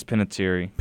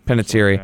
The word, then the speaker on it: Panettiere: I'm sorry, I don't know how to say her last name. – I think it's Panettiere. – Panettiere. Like Hayden Panettiere as Kirby Reed.